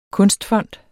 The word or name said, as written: kunstfond